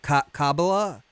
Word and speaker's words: Kabbalah: Steve knows a lot about Kabbalah. I'm probably mispronouncing Kabbalah? – That.